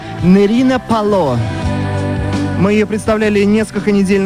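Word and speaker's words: Pallot: Почти как полет, но Пало. Нерина. На самом деле, не удалось, правда, узнать, откуда такое странное имя и еще более странная фамилия. Написано, пишется Палот, но на диске указано, что последнее «Т» не читается.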